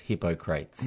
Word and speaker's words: Hippocrates: Even older, perhaps, is a saying from Hippocrates, is that how you say it? – I think you'll find it's pronounced Hippocrates.